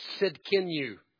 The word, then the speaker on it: Tzidkenu: They shall be called literally Jehovah Tzidkenu.